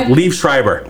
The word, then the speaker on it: Liev Schreiber: Liev Schreiber.